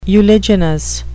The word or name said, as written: uliginous